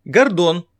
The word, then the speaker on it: Гордон: Гордон. Александр Гордон. Работал вместе со мной на «Серебряном дожде». Я все время говорил ему, что ударение в его фамилии надо делать на первом слоге.